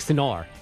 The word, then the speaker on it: Sinner: So I went to the ATP website, and they have Yannick saying his last name, and it has a little bit of a different emphasis on the end. So, yeah, it's Yannick Sinner.